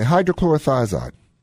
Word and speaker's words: hydrochlorothiazide: It's C-H-L-O-R-I-T-H, and then I kind of can't remember. But it's a diuretic. – Okay, hydrochlorothiazide.